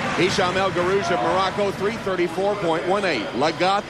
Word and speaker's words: Hisham: How do you pronounce it? – Hisham. Wow. Shocking, Robert. I mean, John is always correct in the pronunciations. I'm shocked on that one. – It's Hisham. You're 100% sure it's Hisham.